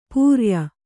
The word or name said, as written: ಪೂರಾಯ